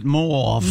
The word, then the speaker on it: mauve: And by the way, you do pronounce it mauve.